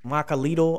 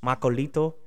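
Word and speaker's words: macuahuitl: And there's different ways to pronounce it. Macalito, I've heard that. Macolito. I'm going to say macauete. Macauete.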